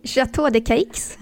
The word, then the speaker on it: Château de Cayx: Men sommaren spenderar hon ju också på sommarresidenset Gråstens slott eller Château de Sceaux. Så har jag rätt nu? – Nej, Château de Cayx.